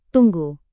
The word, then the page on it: tunggu: indonesian